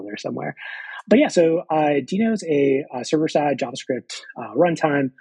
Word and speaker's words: Deno: So yeah, before we dive into new things that are coming to the project, can you tell us about what Deno, it's going to be Deno in my head forever. I know it's one of those where half the community, I feel, still is saying it incorrectly.